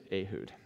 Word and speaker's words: Ehud: I trained him all week to say Ehud, because it's not Ehud Barak, it's Ehud Barak, you know, the former prime minister. So, and I just get to decide pronunciations around here, so... In the staff. You can pronounce it as you want, but it is Ehud.